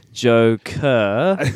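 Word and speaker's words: Joaquin: Joker. – That's how it's pronounced, right? Joker Phoenix? – Yeah.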